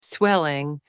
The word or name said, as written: swelling